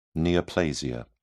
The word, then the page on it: neoplasia: British English